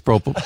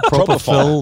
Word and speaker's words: propofol: Propanol. Did I say it right? – A double album last night. – I think it's propofol. Propofol.